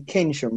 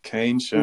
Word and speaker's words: Keynsham: I think mainly for Jay's benefit, it's definitely Keynsham, right? – Keynsham.